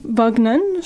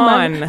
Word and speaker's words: Vaughan: En fait, Paper Girls, c'est un comic qui par Brian Vaughan, je prononce probablement Vaughan. – Vaughan, merci, c'est ça.